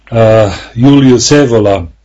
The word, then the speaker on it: Julius Evola: Last time we were discussing about our favorite scholar, at least my favorite, your favorite scholar, author, philosopher, thinker, militant, Julius Evola. I don't know exactly the pronunciation in... – No, you pronounce it well.